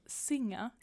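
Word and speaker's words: Singha: A-S-A-H-I. Mm. – jag tror att jag har druckit den. Mm. Eh, Thailand är Shinga. Eller Singa. Singa. Eh, singa. – Mm. Är det sing streck? – Nej, eh, S-I-N.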